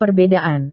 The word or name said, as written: perbedaan